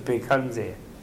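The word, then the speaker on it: Bhaiṣajya: And the person who's sick, they say, Om Baishajya Baishajya Maha Baishajya, although the Tibetans pronounce it Bekhanze.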